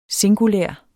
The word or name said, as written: singulær